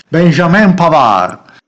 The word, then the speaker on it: Benjamin Pavard: Benjamin Pavard, lo dico proprio con questo, con questo accento francese tanto caro ai Sabaudi, no? ai Savoia. Benjamin Pavard,